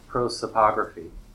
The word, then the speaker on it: prosopography: One of the major purposes of developing the TBDB is to model the historical experience of Taiwanese history through, I have the problem to pronounce it. Can you help me? – Prosopography.